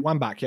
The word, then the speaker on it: Wambach: Wambach, yeah.